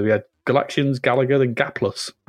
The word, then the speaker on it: Gaplus: I don't know how you pronounce it. Is it Gaplus? Gaplus? – Gaplus? Yeah, Gaplus. Yeah, Gaplus.